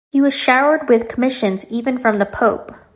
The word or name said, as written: commissions